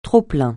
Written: trop-plein

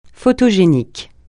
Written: photogénique

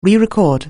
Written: rerecord

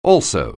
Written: also

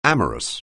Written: amorous

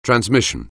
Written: transmission

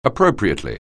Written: appropriately

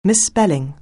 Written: misspelling

